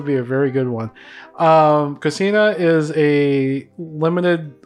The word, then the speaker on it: Kusina: Kusina. K-U-S-I-N-A.